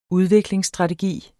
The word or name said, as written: udviklingsstrategi